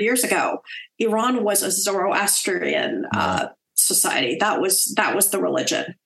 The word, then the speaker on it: Zoroastrian: They're like, we used to be Zoroastrians, and then the Arabs came in with Islam.